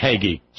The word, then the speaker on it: Hagee: Hagee. Starve! – Hagee. Oh, okay. – Right, it kind of goes with haggis. Hagee. You know, Haggis.